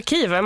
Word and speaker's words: Kyiv: Men det är som kniv. Vi ska tänka kniv när vi säger Kiev.